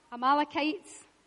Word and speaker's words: Amalekites: The Amalekites, said it right, the Amalekites defeated.